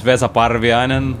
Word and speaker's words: Vesa Parviainen: Okay, my name is Vesa Parvianen.